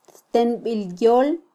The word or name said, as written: ttenbilyol